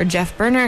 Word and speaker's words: Geoff Berner: We have actually friend of the show and friend of CITR, Geoff Berner, or Jeff Berner, if you were to pronounce that properly, who is going to give a talk about klezmer music, and we'll talk about his upcoming, soon-to-be-published novel, Festival Man.